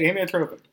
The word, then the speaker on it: amyotrophic: How do you say it? – Well, that's how you say it, amyotrophic.